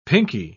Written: pinkie

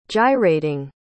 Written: gyrating

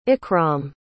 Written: ikram

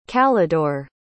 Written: calidore

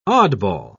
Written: oddball

